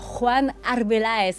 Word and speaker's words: Juan Arbeláez: Et pour ce nouvel épisode, je suis ravie d'accueillir Juan Arbeláez.